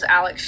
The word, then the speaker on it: Alex: My name is Alex Street, A-L-E-X, last name S-T-R-E-E-T.